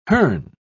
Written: hern

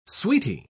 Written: sweetie

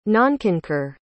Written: nonconcur

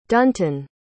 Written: dunton

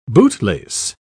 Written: bootlace

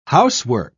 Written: housework

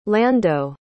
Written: lando

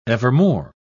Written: evermore

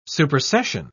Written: supersession

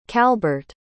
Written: calbert

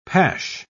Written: pash

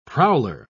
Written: prowler

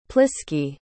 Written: pliskie

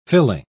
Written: filling

